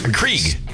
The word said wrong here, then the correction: Keurig: Krieg. – Keurig. – Keurig. Keurig. Yeah. You don't even know how to say it.